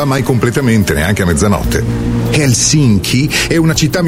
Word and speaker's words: Helsinki: Helsinki. Così lo pronunciano loro.